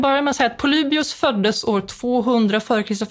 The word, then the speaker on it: Polybios: Polybios.